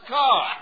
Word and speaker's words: Kerr: And I include Douglas Fairbanks, Jr. and Deborah Kerr, pronounced Carr.